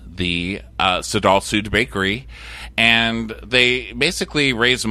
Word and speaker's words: Sadal Suud: Okay, they are called the Sadal Sud Foundation. That is spelled S-A-D-A-L. S-U-U-D Foundation, Sadal Sud.